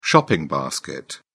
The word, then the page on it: shopping basket: British English